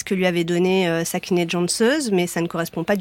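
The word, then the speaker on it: Cansız: Janssöz, vous prononcez Janssöz. – Janssöz. – Et ça s'écrit C-A-N-S-I-Z. Alors, moi, j'ai prononcé à la française. – Bien sûr. – Hein. Mais ça se prononce comme ça.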